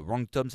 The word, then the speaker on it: Wrong Tom: Wrong Tom, ça s'écrit avec un W. Comme faux, Wrong Tom, W-R-O-N-G-T-O-M.